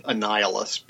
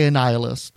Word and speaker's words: Annihilus: I call it Annihilus, because I think it's like Annihilate, Annihilus. – I feel like it's a Magneto thing. I also say Annihilus, and that's the simplest root of the person's name is Annihilation or Annihilate or what have you.